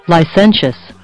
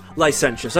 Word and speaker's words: licentious: And let's see how it's pronounced. – Licentious. – Licentious. Okay, I was close.